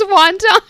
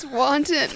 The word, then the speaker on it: wanton: Did you really think it was wonton? oh, you're so cute. That's how it reads. It's wanton.